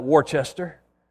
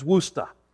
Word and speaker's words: Worcester: Now, some people call that Worcester, or Worcester, but I've been told that the proper pronunciation is Worcester, Massachusetts.